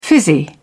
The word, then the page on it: fizzy: British English